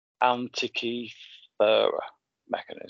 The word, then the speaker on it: Antikythera mechanism: I'm going to butcher how to pronounce this. Antikythera mechanism. – Yeah, that's it. Yep. – That's it.